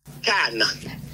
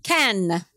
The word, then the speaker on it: Cannes: Cannes. – Cannes.